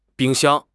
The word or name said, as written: bing1 xiang1